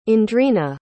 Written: Andrina

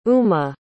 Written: uma

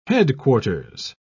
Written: headquarters

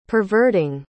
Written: perverting

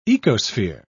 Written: ecosphere

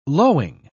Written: lowing

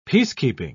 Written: peacekeeping

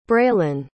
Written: braylen

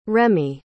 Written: remy

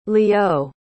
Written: lieux

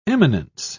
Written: eminence

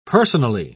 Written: personally

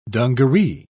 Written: dungaree

